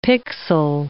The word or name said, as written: pixel